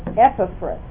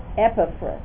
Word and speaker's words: Epaphras: And Paul's friend there, the pronunciation is Epaphras. Epaphras.